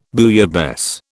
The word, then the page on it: bouillabaisse: British English